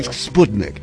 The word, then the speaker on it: Sputnik: Soviet Union like Sputnik. Okay. – That's the Russian pronunciation. – Yes, the cryosphere discussion. – Sputnik. – Tedesco. – That's like the Sean Connery as a Russian guy. Yeah, Sputnik.